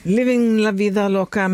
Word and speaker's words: Living La Vida Loca: Living La Vida Loca med Ricky Martin har jag lärt mig att det ska uttalas.